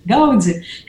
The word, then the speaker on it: Гауди: Ну, во-первых, надо сказать, что фамилия произносится этого известного каталонского архитектора сейчас, ну, по-разному. Кто-то говорит Гауди, кто-то говорит Гауди. Но мы будем говорить вот по старинке. Гауди, как Ирина сказала, так в университете тоже преподавали.